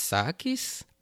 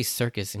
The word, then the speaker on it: Circus: Now, I know in the subtitles, it's, like, pronounced Sakis. I mean, that's how you say it in Japanese, but I'm pretty sure, I mean, that's how you say Circus in Japanese, so I'm just going to call him Circus.